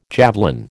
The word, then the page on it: javelin: American English